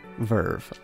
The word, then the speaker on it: VRV: VRV, whichever is more upsetting to the ears. Verve, I believe, is the pronunciation.